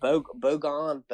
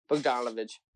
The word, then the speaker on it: Bogdanovich: Bogon, but whatever. – Bogdanovich.